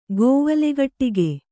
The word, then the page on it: ಗೋವಲೆಗಟ್ಟಿಗೆ: kannada